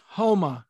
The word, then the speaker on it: Homa: My number two is, and I don't know if this is a hard H or a silent H, but it's Max Homa. Is it Homa or Oma? – It's Homa.